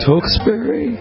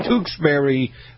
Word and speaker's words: Tewksbury: We've got Fred in Tewksbury. I'm not saying it wrong. – No, you're perfect on that one. Tewksbury, Massachusetts.